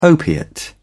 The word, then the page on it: opiate: British English